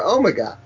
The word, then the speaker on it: Omega: That's right, not Omegar. – Omega. – Omega, okay. – Omega, that's what Omega. Debbie says, doesn't she? – Omega is how Americans would play. Americans say Omega, yeah.